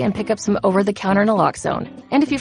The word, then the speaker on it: Naloxone: For the record, it's Naloxone. Nuh, like, is Naloxone addictive? Nuh-uh. Lox, like Naloxone keeps humans safe, like Lox keep bikes safe. And own, like Naloxone can save lives in our very own communities.